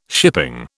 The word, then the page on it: shipping: British English